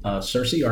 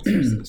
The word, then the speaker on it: Searcy: I'm originally from Searcy, Arkansas. And how'd you spell that? S-E-A-R-C-Y? – S-E-A-R-C-Y. – Oh, Searcy. – Sounds like Searcy, but it's Searcy. – Searcy. – Searcy, Arkansas.